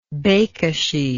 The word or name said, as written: bekesy